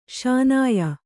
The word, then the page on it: ಶೂನ್ಯ: śunya